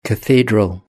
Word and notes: cathedral: British English

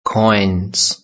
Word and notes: coins: British English

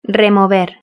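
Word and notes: remover: Spanish (Spain)

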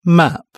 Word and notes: map: British English

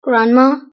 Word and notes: grandma: British English

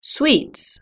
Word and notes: sweets: British English

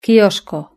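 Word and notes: quiosco: Spanish (Spain)

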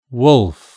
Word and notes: wolf: British English

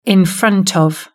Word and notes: in front of: British English

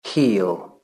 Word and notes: heel: British English

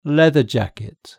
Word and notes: leather jacket: British English

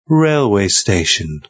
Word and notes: railway station: British English

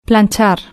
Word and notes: planchar: Spain Spanish

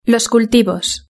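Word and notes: los cultivos: Spanish (Spain)

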